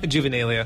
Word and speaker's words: Juvenalia: And the singer, Graham, labeled it Juvenella. How do you say that? – Juvenalia. – Juvenalia. What did I say? – Juvenella.